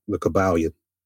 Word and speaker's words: Kybalion: I don't know how much credit you give the Kybalion. Is that how you pronounce it? – Yeah, the Kybalion.